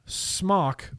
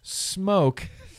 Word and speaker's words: Smok: I feel like Smoke Tech, which once upon a time, Smok or Smoke was called Smoke Tech. Smoke Tech. And that's why I know how to pronounce it Smoke instead of Smok, because they used to call themselves Smoke Tech.